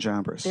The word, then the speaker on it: Jambres: now is, how do you pronounce it? – Jannes and Jambres. – There you go.